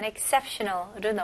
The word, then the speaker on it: exceptional: 다음은 exceptional로 넘어갈게요. 발음 연습부터 해볼게요. Exceptional. Exceptional. 네, 여기서 이 X로 시작하지만 exceptional보다는 exceptional. I 발음으로 주로 많이 발음합니다.